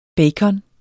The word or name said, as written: BACONEN